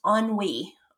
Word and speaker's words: ennui: So it's a French word and it's pronounced ennui, ennui, and it's spelled ennui.